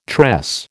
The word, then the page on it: tress: American English